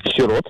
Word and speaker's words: сирот: Во множественном числе данного слова во всех падежных формах ударение падает только на второй слог. Поэтому правильно говорить еще раз как? – Сирот. – Да, да, вы абсолютно правы.